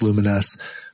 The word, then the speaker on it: Lumines: I mean, the song Rez itself feels like it's a level in the game Lumines or Lumines. Mm-hmm. I think it's pronounced Lumines, which is really, really cool handheld game.